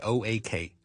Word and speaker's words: oak: 串法系 O A K O A K oak oak oak。 Oak，而呢个发音系同屋咧系完全唔同嘅。